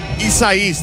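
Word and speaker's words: Isaias: 59 degrees, winds could gust up to 20 miles an hour, showers in the vicinity as the remnants of Hurricane, and Pat's going to correct me, Isisius, I believe is how you say it, Isais, thank you, Pat, is going through the area.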